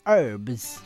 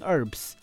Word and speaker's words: herbs: 好，这就是英文单字的习惯啦那个我们就暂且不论到底是英国腔还是美国腔的习惯我们就是这个单字就是念 herbs。好，H 不发音，herbs。